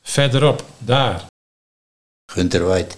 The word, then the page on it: Gunterweit: Nieuw lekkerlands